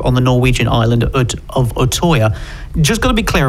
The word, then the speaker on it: Utøya: Utøya. I think it's Utøya. – Obviously Norwegian. – Yes, Utøya, July 22nd.